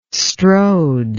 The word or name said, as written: strode